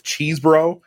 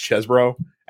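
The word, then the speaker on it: Chesebro: You know, Sidney Powell and Kenneth Cheesebro. By the way, I found out that his last name is pronounced Chesbro, actually.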